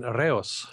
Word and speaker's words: Reus: Lanzarote and Reus.